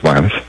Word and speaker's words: Lanes: Spelled Lanes, but pronounced Lannis.